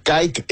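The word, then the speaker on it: Kait: Bueno, pues esto es Nissan Kite, amigos, se escribe con K, A, I latina y T, se llama Kite,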